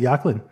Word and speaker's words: Joaquin: So Joaquin Phoenix is, how you pronounce it, Joaquin. – Okay. – You didn't know that? – No. – Yeah, Joaquin Phoenix. It's not Joaquin, it's Joaquin. So anyways, Joaquin Phoenix's standalone Joker film is actually happening.